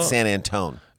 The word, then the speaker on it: San Antone: They call it San Antone. – I'll be in San Antone. Mm-hmm. That is how they say it.